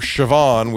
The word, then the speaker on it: Siobhan: The Shiv is short for Siobhan, which is spelled S-I-O-B-H-A-N. How you get Shiv out of that, I don't know.